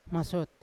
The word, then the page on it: masyot: Yawa